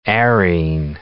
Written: arene

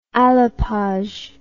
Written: alapage

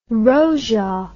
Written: rojak

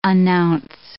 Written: announce

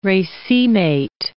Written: racemate

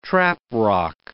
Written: traprock

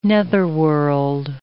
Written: netherworld

